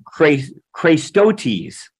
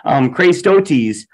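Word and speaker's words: Chrēstotēs: It's pronounced Christotes. Aren't you glad we don't speak Greek? Christotes.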